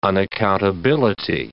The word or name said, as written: unaccountability